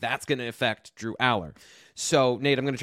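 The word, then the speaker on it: Allar: Huge relief, because we have this conversation and people are like, well, Franklin says Alar.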